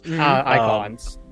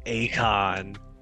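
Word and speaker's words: Eikons: So funny that I've never actually heard how they're pronounced. So I always called them like Akon.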